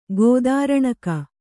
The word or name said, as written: ಕುದುರೆನಗೆ